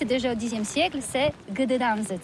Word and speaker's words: Gdańsk: Alors d'abord, comment prononce-t-on bien? Ça s'écrit G-D-A-N-S-K. On dit Gdansk ou Gdansk, on prononce le G ou pas? – Oui, c'est pas très facile pour des étrangers, mais remarque bien que la langue polonaise, c'est la langue où on prononce chaque lettre qu'on écrit. C'est pourquoi on dit Gdańsk.